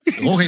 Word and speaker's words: Jorge: Jorge is how you say my name. But because George, innit? – You say George, but my name is Jorge. Spanish. So, George, G-E-O-R-G-E is the English way. My name is J-O-R-G-E, which is Spanish.